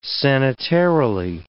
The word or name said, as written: sanitarily